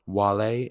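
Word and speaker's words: vale: Wale in Latin, which is directed at a singular person saying goodbye, but walete, which is starts with a V, but in Latin, V's are pronounced as W's.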